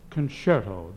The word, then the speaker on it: concerto: Well, I just love that song you sang, Bob, my concerto. – Miss Davis, that's pronounced concerto. The C is pronounced shh, shh.